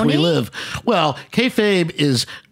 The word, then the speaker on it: kayfabe: Kayfabe, yeah.